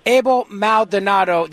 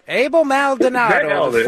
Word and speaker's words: Abel Maldonado: Abel Maldonado. Did I say your name right? Did I nail it or what? – Yes. No, you nailed it. You're good. – I nailed it. Abel Maldonado, former Lieutenant Governor of California.